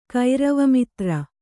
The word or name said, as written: ಕೈರವಮಿತ್ರ